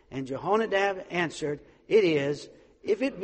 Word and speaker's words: Jehonadab: Jehu, and he is, the story's about Jehu, and he comes upon a well-known man, and his name is Jehonadab, and he is the son of Rechab, and so that's how we're going to pronounce those.